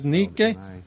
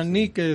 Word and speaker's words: nike: Una vez más, esa palabra victoria en el griego es nike, se escribe N-I-K-E, mal pronunciado en la cultura moderna como Nike, pero es Nike.